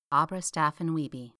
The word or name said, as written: Abra Staffin-Wiebe